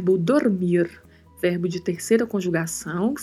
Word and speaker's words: dormir: Chamo a atenção mais uma vez, assim como comentei no episódio anterior, para a pronúncia da letra D, que é sempre pronunciada com um pouco mais de suavidade, não muito marcado. Dormir. E o R, vibrato, sempre. Dormir. Seguimos na frase com mais um advérbio, outro advérbio, que é mucho, que está seguido de mais um advérbio, que é mas.